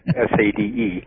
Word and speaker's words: Sade: And at one point, one of them telepathically told her to call either her or the group Sade, S-A-D-E, she said is how it's spelled.